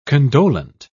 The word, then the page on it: condolent: British English